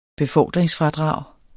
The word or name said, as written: befordringsfradrag